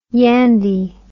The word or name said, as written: yandy